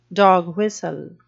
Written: dog whistle

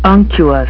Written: unctuous

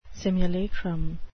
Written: simulacrum

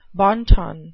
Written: bon ton

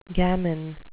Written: gammon

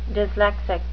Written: dyslexic

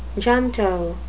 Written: junto

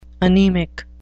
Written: anemic

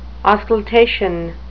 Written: auscultation

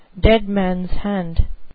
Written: deadmans hand

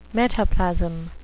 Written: metaplasm

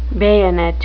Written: bayonet

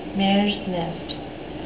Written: mares nest

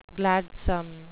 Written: gladsome

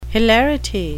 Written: hilarity

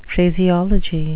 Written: phraseology

